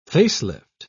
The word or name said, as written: face-lift